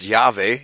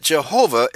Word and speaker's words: Yahweh: To give the name J-H-W-H, or you could say J-H-V-H, because it is pronounced Yahweh in the original language, the word for Lord, Hebrew Adonai, and pronounce it Jehovah